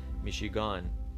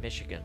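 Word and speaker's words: Michigan: آنچه ما میگوییم میشیگان آنچه آنان میگویند میشیگن گن میشیگن آنچه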